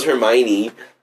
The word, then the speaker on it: Hermione: I was like, Hermione. – Dude, I couldn't pronounce her name. – Hermione. Hermione. Like, I didn't know it was Hermione.